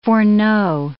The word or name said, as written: foreknow